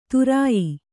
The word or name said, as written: ಧೌರೇಯ